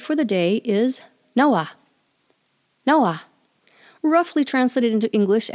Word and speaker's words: nawa: They had nawa. If you were to spell it, it would be N-A-W-A. The Pawnee short A says like the English A in above, about, what, was, the sound. In pre-contact times, it was pronounced rawa, spelled R-A-W-A, and you'll still see this pronunciation in some of the older dictionaries.